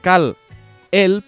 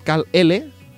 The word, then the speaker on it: Kal-L: Hay otra, otra generación del personaje cuyo nombre es Kal-El, pero sin la E, es Kal-L, no Kalel, Kal, por decirlo de alguna forma.